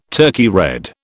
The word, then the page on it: Turkey red: British English